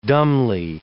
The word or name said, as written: dumbly